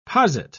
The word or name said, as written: posit